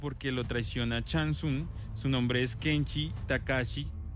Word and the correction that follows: Takahashi: Takahashi, perdón.